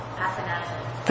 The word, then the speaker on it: Athanasian: Athanasian.